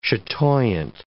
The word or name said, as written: chatoyant